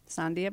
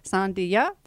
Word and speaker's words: Sandija: First name is S-A-N-D-I-J-A. So S-A-N-D-I-J-A. Last name Bayou, B-A-Y-O-T. Again, French B-A-Y-O-T. Sandhya Bayou. And it rhymes from Latvia. And I actually say this, Sandhya from Latvia.